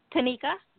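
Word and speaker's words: Taneka: This is Tanika. – I'm sorry, your name faded. – Tanika? – How do you spell that? – T-A-N like Nancy, E-K-A.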